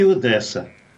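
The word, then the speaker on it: Одеса: Дело в том, что по-русски говорят Одесса. Ну, вот русское произношение. А по-украински Одесса.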